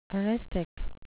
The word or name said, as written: eristic